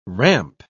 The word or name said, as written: ramp